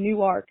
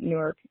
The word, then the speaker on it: Newark: It's in Newark, Delaware. We don't pronounce it Newark, and there is no airport.